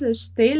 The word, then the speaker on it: achetez: Bom, vous achetez, então aqui mesma coisa do nu, não vai pronunciar o e, porque ele não vai ter acento, né? Então, vous achetez le pain à la boulangerie.